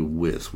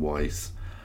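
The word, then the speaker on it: Wyss: Both of us have been mispronouncing her last name, apparently. Diane Franklin had let him know her last name was actually Wiss. We were both saying Weiss.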